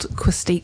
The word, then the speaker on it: quercetin: So it's called quercetin? – Quercetin. – Quercetin. – Yeah, Q-U-E-R-C-E-T-I-N.